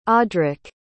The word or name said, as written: audrick